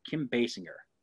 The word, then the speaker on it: Basinger: Kim Basinger.